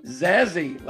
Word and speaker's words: Zazie: She pronounces it Zazzy, like Jazzy, but it starts with a Z.